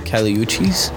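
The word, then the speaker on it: Kali Uchis: How do you say it? – Cali Uchis.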